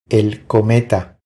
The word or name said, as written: El cometa